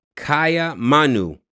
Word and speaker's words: Kaiamanu: It was called Kaya Manu, which is K-A-I-A-M-A-N-U.